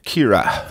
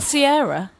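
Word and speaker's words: Ciara: Please pronounce it correctly. – Kira. – Thank you so much. – Not Sierra? – No. – Like the pop singer? – No.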